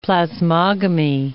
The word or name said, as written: plasmogamy